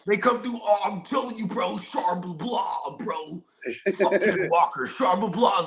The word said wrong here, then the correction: Charbonnet: Bro, it's pronounced Charbonnet, bro.